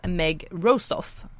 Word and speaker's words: Meg Rosoff: det var Någonting vad som helst av Meg Rosoff, uttalas det så? – Ja, just det.